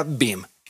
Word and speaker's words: BIEM: Para hacerlo más sencillo, se pronuncia BIM, y las siglas son B-I-E-M.